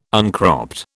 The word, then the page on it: uncropped: British English